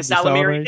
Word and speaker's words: Ysalamiri: Ysalamiri. There you go. Ysalamiri skin underwear. Except don't the Ysalamiri have to be alive for it to work? – Ysalamiri.